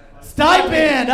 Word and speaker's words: stipend: Am I pronouncing that right? I've only read that word before. – Stippend. – Stippend. – Who can say Stippend? Say it out loud if you know it. Stipend! Oh, right.